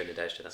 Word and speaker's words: Nadezhda: Nadezhda. Yeah. Cool? I tried to find a pronunciation online again. – Nice. I'm going to go Nadezhda.